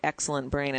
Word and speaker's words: XLNT Brain: I just realized when you said it, Excellent Brain, and I was saying XLNT Brain because that's how it's spelled in caps, which I assume is an acronym for something. – Exactly. Well, it's Excellent Brain, XLNT Brain.